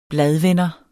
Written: bladvender

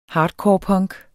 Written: hardcorepunk

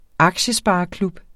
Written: aktiespareklub

